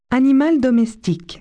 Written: pet

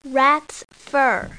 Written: rats fur

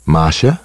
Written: Marsha

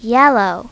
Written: yellow

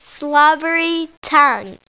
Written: slobbery

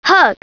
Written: hug

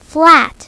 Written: flat

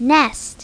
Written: nest

